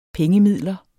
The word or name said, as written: pengemidler